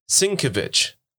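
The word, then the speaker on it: Sienkiewicz: And like looking at thing, like it's pronounced Sinkevich. – Sinkevich. – God bless you. Or Sinkevich. Because the K-E-V in the middle for the pronunciation is all capitals.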